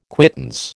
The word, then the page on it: quittance: American English